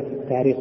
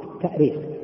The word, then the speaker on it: التأريخ: أيهما أصح في الكتابة والنطق في كلمة التاريخ في الهند والتأثير؟ طبعا من حيث الأصل الاشتقاقي التأريخ، التأريخ بالهمز لأنها أرخ يؤرخ، الهمز أصلي فيه، أرخ يؤرخ تأريخا. وأما التاريخ فهو تسهيل،